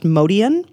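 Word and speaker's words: Motian: It looks like motion spelled wrong, but it's pronounced Modian.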